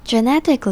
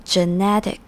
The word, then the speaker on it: genetic: 这边的 genetic 指的是基因的，那 gene G-E-N-E 是基因的意思，那我们后面加一个 T-I-C 它就变成形容词 genetic. genetic 里面第一个 E 发成耳的声音，那第二个 e 的发音不一样 genetic，所以第二个 e 发成 a 的声音，所以是 genetic.